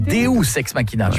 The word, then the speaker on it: deus ex machina: Deux ex machina. Non, deus ex machina. – Ah, parce que deux, j'aurais dit que c'était deux ex que t'appelles machine. Tu vois. – Ça se prononce deus ex machina.